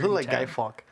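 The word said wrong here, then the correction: Fawkes: Yeah, Fox. Sorry, I said it wrong.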